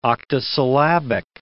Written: octosyllabic

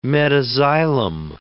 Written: metaxylem

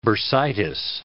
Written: bursitis

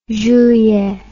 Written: julien

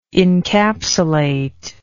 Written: incapsulate

